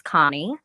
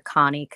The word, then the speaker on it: Cahni: My name is Cahni. On Facebook, you can find me under Cahni Katana. It's spelled C-A-H-N-I, so that's not a mistake.